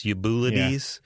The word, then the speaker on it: Eubulides: It's Eubulides.